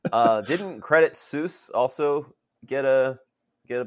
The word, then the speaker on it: Credit Suisse: Thanks, Coil Spotting it, it, if you pretend you're French and you have a cigarette in your hand, it's Credit Suisse.